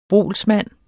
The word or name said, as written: BOLSMANDENS